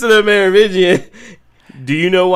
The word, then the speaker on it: Merovingian: And they meet the Merovingian. Did I say that right? – I believe so. The Merovingian, yeah. – The Merovingian.